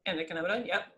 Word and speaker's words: endocannabinoid: Endocannabinoid.